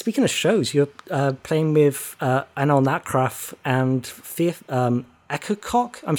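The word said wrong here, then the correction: Akercocke: Oh, is there an extra syllable in there? I had no idea. – There's an E on the end of it, which I don't know. Everyone I know pronounces it as Echococker, so I feel like...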